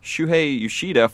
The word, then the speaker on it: Shuhei Yoshida: Shuhei Yoshida falling asleep.